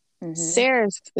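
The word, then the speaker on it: Saoirse: Cersei? S-A-O-R. Cersei. I think it's like Cersei.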